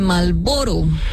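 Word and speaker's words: Marlboro: Más ignorada que la R de Malboro. – Oye, sí, es que una R es que no se pronuncia. – ¿No se pronuncia? – Malboro, nadie dice Malboro.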